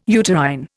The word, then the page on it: uterine: Indian